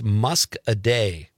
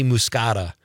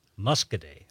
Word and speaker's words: Muscadet: I was saying, how would you say this, Bob? M-U-S-C-A-D-A. – I would defer to you. – I kind of just told you. I'm wrong. I always want to say muscata, probably because I'm thinking of the wine, but I've been corrected several times on this show. Pete, it's musk a day.